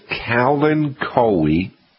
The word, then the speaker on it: Kalanchoe: The name of the plant is, you can pronounce it Kalanchoe or Kalancho, but either one, Kalanchoe or Kalancho.